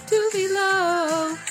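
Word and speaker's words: Tove Lo: Hey, how do you pronounce Tuve Lu's name? Tuve Lu. That would say Tuve Lu.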